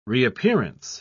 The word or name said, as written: reappearance